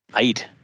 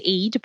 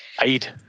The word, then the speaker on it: Eid: And I don't know if it's different, a different region or a different country, but they don't actually say Eid. They say Eid. Is that the actual? – Yes. So it's basically where it's the pronunciation of it.